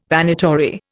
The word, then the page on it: Vanitory: Indian